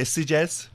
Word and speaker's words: Sitges: But you pronounce it Sitges. – Yeah, well, it's weird because the locals say Sieges. And then, that was what I was always told it was.